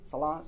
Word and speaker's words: Salat: Their prayers would be pronounced, you might pronounce this for us, S-A-L-A-T, Salat, or Salat. The Saum is in fasting, S-A-U-M.